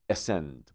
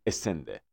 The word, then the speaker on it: ascend: همونطور که به لهجه فارسی میگیم ascending descending. همیشه اینو خوندیم دیگه، نزولی صعودی، میگیم ascending descending. تلفظ درست امریکایی این ascend. Okay, ascend. وقتی فعلشو میگی ascend.